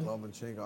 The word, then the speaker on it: Lomachenko: No, how you say his name? Lomachenko? – Lomachenko.